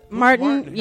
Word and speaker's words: Martin: Am I saying that right? Martin? – Martin, yes, Martin Harris.